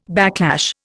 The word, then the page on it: backlash: Indian